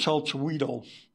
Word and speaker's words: Chalchihuitl: So in this area of the Cerrillos Hills is Mount Chalchihuitl, correctly pronounced.